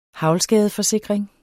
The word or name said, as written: haglskade